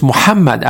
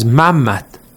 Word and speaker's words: Muhammad: For example, we cannot pronounce Muhammad as Mammat, or we can say Hussein as, for example, Hussein or something like that in a shortened form.